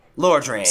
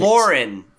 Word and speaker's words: Lordran: Lordran. Sorry, I couldn't let you butcher that in front of me. – Lauren.